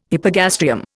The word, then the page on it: hypogastrium: Indian